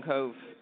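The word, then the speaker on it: Cobh: I'm from a place called Cove in County Cork. – Is that how you pronounce it? – That's right, yeah. – Cove.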